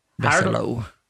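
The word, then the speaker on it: Bessolo: George Reeves' mother, Helen Besola. – Besolo. – What is it? – Helen Besolo. – Besolo, I'm sorry.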